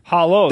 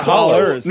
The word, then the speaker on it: hollow: Hollow, not hollers. – No, not holler. Hollow.